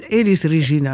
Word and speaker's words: Elis Regina: Eh, Alice Regina. Eh, zeg ik dat goed zo, Regina? Elis, Regina.